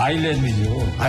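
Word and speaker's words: island: 제목은 그 아일랜드, 아이슬랜드 인더스트림. – 아이슬랜드가 아니죠. – 뭡니까? – 아일랜드죠. – 아일랜드니까? 왜 근데 아이슬랜드는 묵음이죠, 묵음. – 그거 언제 묵음이 됐냐, 그거? – 예, 옛날부터 묵음이 됐어요.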